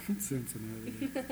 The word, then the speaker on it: Cincinnati: Cincinnati. – Is that how you really pronounce it? – Cincinnati.